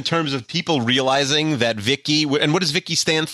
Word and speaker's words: VICI: voice input child identicant, uh, and it's pronounced V I C I, but it's pronounced Vicky.